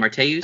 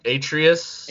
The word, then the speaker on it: Atreus: We're incredibly grateful to see so many people excited to experience the next chapter of Kratos and, how do I say his name, Arteus? – Atreus.